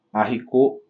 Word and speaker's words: haricot: E eu acho que eu Il y a un erreur de pronúncia aqui em relação ao Arrico. O il est é escrito un H, et on dit le haricot. Le H de haricot est aspiré.